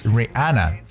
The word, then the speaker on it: Rihanna: 那另外就是知道这个美国非常出名的一个歌星 Rihanna，Rihanna，千万不要读 Rihanna，it's Rihanna。